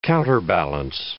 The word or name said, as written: counterbalance